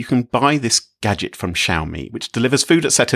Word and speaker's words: Xiaomi: Is that how you say it, by the way? Xiaomi? – Yes, yes, Xiaomi.